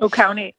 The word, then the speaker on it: Oconee: I think it's called Oconee. I don't know how to say it, but Oconee. Yeah. Oconee County.